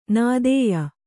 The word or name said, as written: ನಿತ್ಯೆ